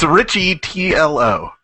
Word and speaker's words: Riccitiello: So is that the pronunciation then? – I think it's Ricciatiello. – It's Ricciatiello. – Yeah. I've always heard it as John Ricciatiello. – I thought the double C made it like Christina Ricci.